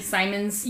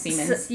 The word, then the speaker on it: Siemens: There's that company, Simons. – Yeah. – Which I'm pretty sure they pronounce it Siemens. – Yeah, they do. – S-I-E-M-E-N-S.